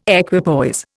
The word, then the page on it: equipoise: Indian